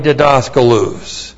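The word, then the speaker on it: didaskalos (διδάσκαλος): Poimen, P-O-I-M, long E-N, poimen, and then didaskalos. D-I-D-A-S-K-A-L-O-S, didaskalos.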